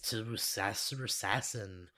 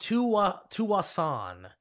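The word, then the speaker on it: Tsawwassen: But here's the actual pronunciation of this Tsusassan place in Canada, I guess. British Columbia. It's pronounced Tuwasan. – Tuwasan. – The S is silent.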